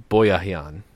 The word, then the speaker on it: Boyajian: Okay, it's B-O-Y A J I A N, Boyajian. – It's Boyajian.